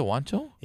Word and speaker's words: Juancho: Is it Juancho? – Yeah, that's how you say it.